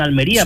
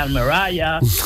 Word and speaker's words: Almería: palabra Almería, y fíjate que la ha pronunciado bien, Almería, sí, porque sí, un extranjero sí diría Almeraya,